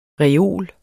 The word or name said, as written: reol